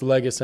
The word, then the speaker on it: Legas: I usually just tell people it's Legas anyways. It's just a lot simpler.